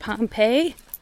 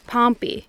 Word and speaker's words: Pompey: How is it pronounced? Pompeii? Pompey.